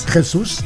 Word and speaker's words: Jesus: Jorge Jesus, zeg ik dat goed? – Jesus. – Ja.